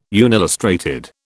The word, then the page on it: unillustrated: British English